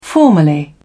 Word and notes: formally: British English

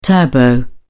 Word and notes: turbo: British English

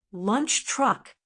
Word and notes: lunch truck: American English

